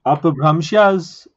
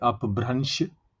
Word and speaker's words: Apabhramshas: Apabhramshas, some people also pronounce it like Apabhransh.